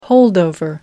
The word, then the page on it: holdover: American English